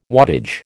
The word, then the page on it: wattage: American English